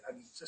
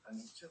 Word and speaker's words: anicca: Questo, per inciso, è perfettamente coincidente con le dottrine, diciamo così, buddista, con i tre segni dell'esistenza, dukkha, anicca, mi dicono bisogna dire anicca, si pronuncia anicca, quindi dukkha, anicca, anicca.